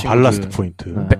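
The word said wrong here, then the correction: Ballast: 정확히는 밸러스트 포인트입니다.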